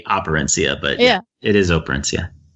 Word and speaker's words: Operencia: Am I pronouncing that? Is it Operencia or Operencia? – It's Operencia. – Operencia.